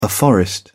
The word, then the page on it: afforestation: British English